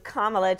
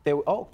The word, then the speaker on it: Kamala: Just think of a comma and then add a la. – Kamala. – There you go. Kamala. – I like that. – There you are. – It's like long A.